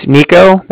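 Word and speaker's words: Niko: All right, my name's Nico. That's N-I-K-O.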